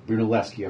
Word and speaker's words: Brunelleschi: Yeah, and they go back to the Brunelleschi thing, mm-hmm. if that's the right way to pronounce it. – Brunelleschi, of course yeah.